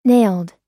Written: nailed

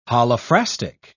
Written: holophrastic

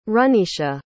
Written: runisha

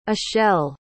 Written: achelle

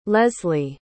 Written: lesly

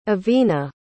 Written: Evina

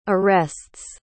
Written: arrests

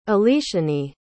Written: aleshanee

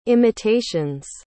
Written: imitations

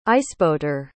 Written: iceboater

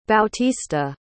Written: bautista

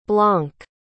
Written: blanc

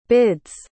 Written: bid's

